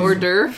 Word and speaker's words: hors d'oeuvre: What's that word again, where it starts with an H but you don't pronounce it? – I have no idea what you're saying. – Hors d'oeuvre.